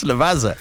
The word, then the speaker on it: Lavazza: Well, the Italian coffee roaster, Lavazza, am I saying that right? – No, there's no I in it. – How do you say it? – It's Lavazza.